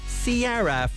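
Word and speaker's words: Ciara: Missy Elliott teaming up with, it's spelled Kira, but it's pronounced Sierra, from her album My Goodies.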